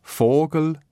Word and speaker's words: Vogel: Es gibt klare F-Aussprachen bei Vogel, Vers, Vielfraß, Vater, Vier, Volk und bei all diesen Wörtern mit Vorsäuben Ver oder Vor.